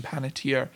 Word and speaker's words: Panettiere: So she's at the Shania Twain, and like Hayden Panettiere, that's how you say it, is like the Miley Cyrus.